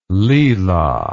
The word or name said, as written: lila